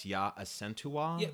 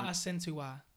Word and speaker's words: Asantewaa: Okay, and her name is pronounced Asantewaa. – Yeah, Asantewaa.